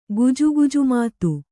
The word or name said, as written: ಗುಜುಗುಜುಮಾತು